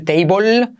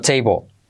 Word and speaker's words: table: Cuidado no decir table, sino table. La L casi no se pronuncia al final.